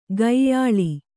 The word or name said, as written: ಕೊಯ್ಯಾಳ್